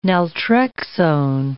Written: naltrexone

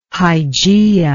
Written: hygieia

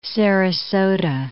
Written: Sarasota

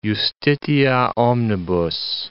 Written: justitia omnibus

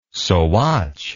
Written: sawatch

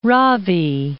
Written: Ravi

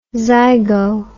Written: zygo

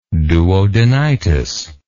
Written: duodenitis

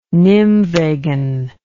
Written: nymwegen

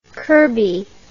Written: kirkby

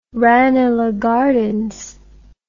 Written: ranelagh gardens

